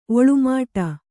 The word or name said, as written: ಒಳ್ಮಿಡಿ